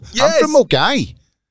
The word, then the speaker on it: Milngavie: M-I-L-N-G-A-V-I-E. – Oh, right, okay, that's interesting. Yeah. So you're not pronouncing it the way it's spelled.